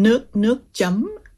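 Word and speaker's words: nước chấm: Okay, so actually, you mentioned, how do you pronounce it? Nuk chum? – Nuk chum.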